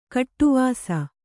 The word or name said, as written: ಗೆಡೆವಸು